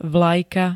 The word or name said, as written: vlajka